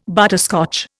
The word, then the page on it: butterscotch: Indian